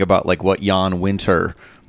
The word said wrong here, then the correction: Wenner: Or Wenner? – You mean?